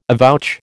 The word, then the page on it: avouch: American English